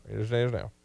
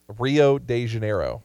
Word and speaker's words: Rio de Janeiro: Rio de Janeiro. I didn't say it right. Rio de Janeiro. – There we go.